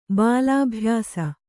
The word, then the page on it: ಬಾಲಾಭ್ಯಾಸ: Kannada